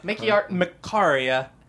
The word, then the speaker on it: MCCARYA: How do you say that as a word? – Mickey Macaria. – Oh. – Because it's half my last name, half my first name.